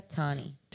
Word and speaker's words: Tawni: Eh, porque el nombre se escribe T-A-W-N-I, ah, pero se pronuncia Tony.